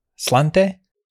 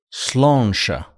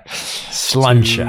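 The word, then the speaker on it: Sláinte: Is it pronounced... Slante? – Slonsha. – You're the one who's been oppressing that island for hundreds of years. So, yeah. Slonsha. – Touché. Okay. Slonsha.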